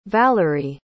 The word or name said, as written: valarie